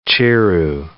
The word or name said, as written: chiru